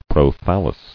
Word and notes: Prothallium: Pro*thal"li*um

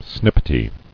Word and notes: Snippety: snip·pet·y

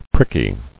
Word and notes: Pricky: prick·y

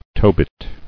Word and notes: Tobit: To·bit

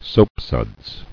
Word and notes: Soapsuds: soap·suds

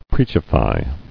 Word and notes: Preachify: preach·i·fy

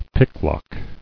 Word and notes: Picklock: pick·lock

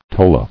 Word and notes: Tola: to·la

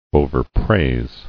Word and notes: Overpraise: o·ver·praise

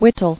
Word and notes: Wittol: wit·tol

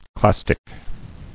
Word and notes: Clastic: clas·tic

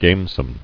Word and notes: Gamesome: game·some

